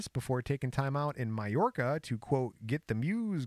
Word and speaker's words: Mallorca: And it's spelled Majorca, so it's Mallorca.